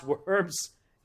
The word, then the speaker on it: Worms: He went downriver to a town called Worms, spelled W-O-R-M-S, but not pronounced Worms. It's Worms.